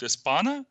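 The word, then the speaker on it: España: Вот, если, например, мы не переходим на то название, которое есть, ну, например, у той же Испании, да, вот эта страна по-испански будет «Испана», по-немецки это «Шпаниен».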